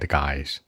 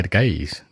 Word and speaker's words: guys: 我们知道有很多的什么 bad guys, 注意很多坏人呢叫 guys, 很多人发音总会说 bad gays, 这是坏坏坏坏 gay, 是吧？注意区别，千万别说错了。